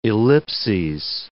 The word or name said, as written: ellipses